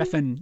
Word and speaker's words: Refn: So the Best Director Award at Cannes went to a Danish filmmaker named Nicholas Winding Refn. – Refn, yes. – Am I saying that right? – Yes.